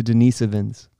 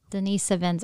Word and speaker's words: Denisovans: Did I say that right that time? – Kind of. – Say it for me again. – The Denisovans. – Denisovans, okay.